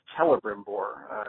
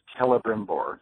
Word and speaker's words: Celebrimbor: His name is actually pronounced Celebrimbor, an Elvish, at least Tolkien's Elvish. Elven names that start with a C, actually the C is sound like a hard K. So his name is Celebrimbor, just in case you're curious.